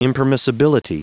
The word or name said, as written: impermissibility